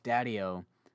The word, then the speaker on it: Daddio: For some reason, I want to, like, emphasize the O, like Daddio, but it's Daddio.